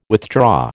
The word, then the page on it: withdraw: American English